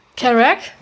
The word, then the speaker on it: Kerouac: Kerouac. – Kerouac, genau, Kerouac.